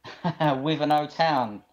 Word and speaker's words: Wivenhoe: Wivenhoe Town.